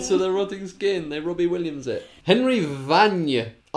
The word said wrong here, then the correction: Vignes: I am not 100% sure how that's pronounced. V-I-G-N-E-S. – Oh, Vignes. – Thank you, Vignes.